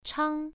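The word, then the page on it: chang: tone 1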